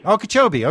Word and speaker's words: Okeechobee: Okeechobee.